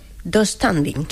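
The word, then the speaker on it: döstädning: Yo lo pronuncio... Dos standing. – Sí, así. Sí, tengo esa silla del IKEA, sí, sí, se pronuncia así.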